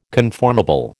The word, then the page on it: conformable: American English